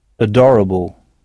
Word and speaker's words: adorable: Mais notez bien que le A initial se prononce E, adorable.